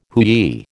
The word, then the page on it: hooey: American English